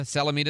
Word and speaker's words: Salamita: It's like we need the nuclear vessels in Alameda. Salamita, is the right way to say it? – Yeah, that's correct.